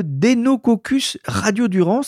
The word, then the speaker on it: Deinococcus radiodurans: que certains connaissent et le Deinococcus radiodurans.